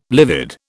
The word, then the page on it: livid: British English